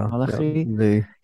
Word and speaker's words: Malachi: And the final word spoken by the final prophet in the Tanakh, Malachi, right? How do you pronounce that in English? Malachi? – Malachi.